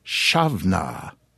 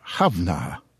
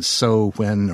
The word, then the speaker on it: Samhain: According to those who have studied the matter, or who just know such things, the barely pronounceable Samhain should be pronounced Shavna, if you're a male, or Havna, if you're a female. However, as I understand it, nowadays most Gaelic-speaking folk, men or women, simply pronounce it Shavna.